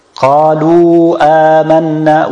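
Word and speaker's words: Qalu amanna (قالوا): This one is not pronounced. Qalu amanna. We don't say qala wa. We say qalu amanna.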